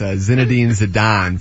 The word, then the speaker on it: Zinedine Zidane: Zildane, whatever. What is it? Zildane? – Yeah, Zildane is turning 46 today. That's Zinedine Zidane, for those of you keeping score at home.